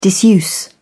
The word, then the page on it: disuse: British English